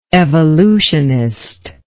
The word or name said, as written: evolutionistically